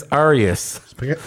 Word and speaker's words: Arceus: The K is silent. It's Arceus. – Specifically because they didn't want it to sound like arse.